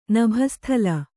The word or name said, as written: ನಭಸ್ಥಳ